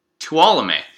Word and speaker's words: Tuolumne: And then all the way down to Tolumne. Tolumne. Which I don't, I'm so sorry. I don't know how to pronounce that. – T-U-O-L-U-M-N-E.